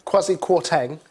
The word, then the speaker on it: Kwarteng: The business secretary, Kwasi, how do you say his second name? – Kwasi Kwarteng, yeah.